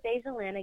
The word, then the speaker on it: Dajulyn: Oh, my name is Dajulyn again, and my social media is spelled Dajulyn, and a way to remember it has July in it.